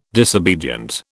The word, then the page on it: disobedient: British English